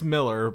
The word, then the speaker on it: Millar: And then I had to describe to her like what Mark, is it Mark Miller or Mark Millar? – It's Miller, but it's okay, Miller. – But it's spelled Millar.